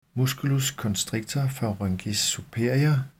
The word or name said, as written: m. constrictor pharyngis superior